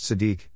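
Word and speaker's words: tzedek (צדק): It is derived from a three-letter verb root, tzedq, pronounced tzedek, meaning righteous, to be right, just, or upright.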